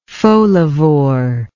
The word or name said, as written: folivorous